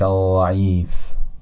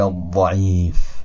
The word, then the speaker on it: الضعیف: و حالا کلمه ضعیف، اول اشتباهش رو تلفظ میکنم بعدن صحیح شد. دقت بفرمایید، یا لطیف ارحم عبدک الضعیف. حالا میگم یا لطیف ارحم عبدک الضعیف با فشردگی بیشتر.